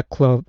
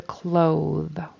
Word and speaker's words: cloth: I heard a clothe with a long O and a voiced TH. We want a short O and an unvoiced TH. Cloth.